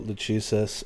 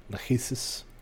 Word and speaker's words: Lachesis: Clotho, Lachesis, and Atropos. – Thank you. So we draw... – Lachesis.